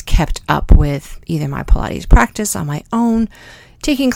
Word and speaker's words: Pilates: It is spelled similar to Pontius Pilate, but it's not. It's pronounced Pilates.